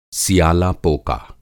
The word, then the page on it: सियालापोका: Hindi